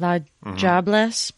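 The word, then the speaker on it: diablesse: So it's pronounced with like a J sound, like la jobless, but it is spelled not that, with a D. – Yeah, it's D-I-A-B-L-E-S-S-E.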